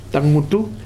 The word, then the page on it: Tagwutu: Futuna